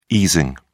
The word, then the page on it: easing: British English